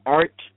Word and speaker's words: ARTE: And then also, there's, I guess I'm pronouncing this right, ARTE TV, A-R-T-E.